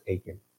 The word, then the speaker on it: Achan: And if you think back to, Joshua chapter 7, in verses 1 to 26, we see the story of, I think his name's pronounced Achan. I can't remember. Yes? Thanks.